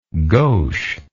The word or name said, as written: gaucheness